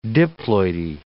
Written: diploidy

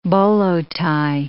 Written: bolo tie

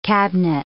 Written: cabinet

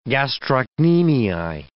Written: gastrocnemii

